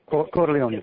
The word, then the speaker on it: Corleone: Yeah, Corleone, very good.